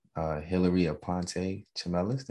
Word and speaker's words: Hillary Aponte Chimelis: And today's guest is Hillary Aponte Chimelis.